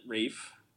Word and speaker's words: Ralph: I'm sure it's mm. not Ralph, but I'm American, so and they, this film criticizes Americans enough, so. uh, My English brain can't Amer- get around Americans, isn't it? How to pronounce his name? Like Rafe like yeah.